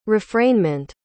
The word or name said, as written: refrainment